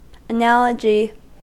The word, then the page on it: analogy: American English